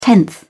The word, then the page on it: tenth: British English